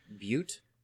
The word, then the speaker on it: Bute: I don't know how do you pronounce B-U-T-E. – Bute? – Bute.